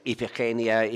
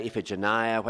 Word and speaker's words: Iphigenia: you can pronounce in various ways, Iphigenia, Iphigenia, whatever way you want to pronounce her name, that she was going to marry the warrior Achilles.